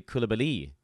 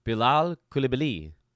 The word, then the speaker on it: Coulibaly: And the issue is that his last name is getting Americanized, which tends to happen. So a lot of people are calling Koulibaly, which is not the French pronunciation. That's more of an American thing. Koulibaly is how we do it here in the States. But in the Wizards media guide, the accent actually is on the last syllable, believe it or not. So it actually Koulibaly. Bilal Koulibaly is the correct pronunciation of his last name.